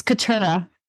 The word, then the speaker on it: Keturah: Keturah, how do you like to, am I pronouncing your name right? Or is that the correct answer? – It's Keturah. – Keturah. Okay, great.